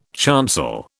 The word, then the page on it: chancel: British English